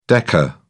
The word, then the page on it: deca: British English